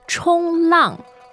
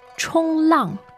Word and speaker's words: 冲浪: 冲浪. 冲浪. – 冲浪. Mm. That sounds like a first tone and a fourth tone. Is that correct? – You are right.